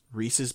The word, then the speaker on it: Reese's: it rhymes with pieces, Reese's Pieces, but I did run into someone who called them Reese's Pieces, and I was like, I'm fucking done.